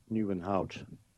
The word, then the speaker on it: Nieuwenhout: En mevrouw Nieuwenhout is er ook. – Ja, complimenten in één keer goed uitgesproken.